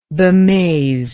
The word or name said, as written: bemazed